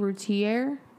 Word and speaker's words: Routier: So, tonight's episode is going to be about Darlie Routier.